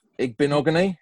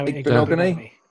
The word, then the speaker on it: Igbinoghene: Noah Igbinoghene. Igbinoghene? – No, Igbinoghene.